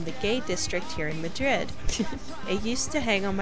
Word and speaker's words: Madrid: The Madrid in New Mexico is actually pronounced Madrid.